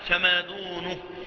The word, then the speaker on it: فما دونه: أو كان الحرير علما أربع أصابع فما دونه، تنطق هكذا. نعم، زي بعده كده، مش بالهاء، قلتش فما دونه، قلت فما دونه.